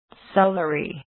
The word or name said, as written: celery